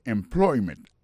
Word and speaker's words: employment: Alors, mot employment là, c'est employment. Accent tombé sous deuxième syllabe là.